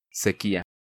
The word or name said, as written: Sequia